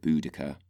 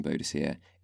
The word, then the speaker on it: Boudicca: People far wiser and more learned than me have basically agreed that Boudicca is the correct pronunciation. So my commiserations to those of you who were backing Boadicea, it's Boudicca, I'm sorry. That is in terms of how the language would have sounded, in terms of the Celtic pronunciation.